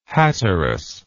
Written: Hatteras

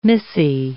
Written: missy

